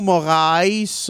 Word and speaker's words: Moraes: Un combat de Marlon Moraes. Euh, c'est ça, c'est comme ça qu'on le prononce, monsieur.